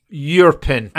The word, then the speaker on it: Irpin: Sergei explains of how he evacuated his parents from Irpin, and he pronounced it almost like Yurpin.